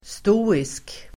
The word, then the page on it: stoisk: [st'å:isk]